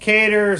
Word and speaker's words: Kattar: Kattar. Some people pronounce it Kattar. Mm. Yeah, it's Kattar.